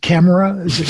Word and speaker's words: Kamara: You know, it's like, how do we pronounce it? Is it Kamara? – I go Kamara.